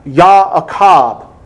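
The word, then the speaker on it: Ya'akab (יַעֲקֹב): And what I mean is Jacob's name in the Hebrew is pronounced Ya'akab.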